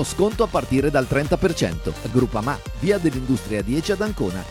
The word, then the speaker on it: Groupama: Ah no, si pronuncia Groupama.